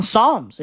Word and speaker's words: Psalms: Like the P on Psalms.